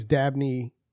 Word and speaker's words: Dabney: But I did hear you say the name Hagney. The real name is Dabney.